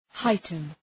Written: heighten

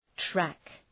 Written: track

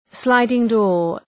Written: sliding door

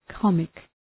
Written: comic

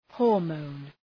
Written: hormone

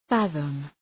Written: fathom

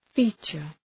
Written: feature

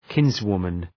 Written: kinswoman

